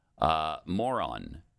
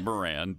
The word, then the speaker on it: Moran: Moron. It's Moran, but I'm going to pronounce it moron in this particular case. – I think that is how he pronounces it.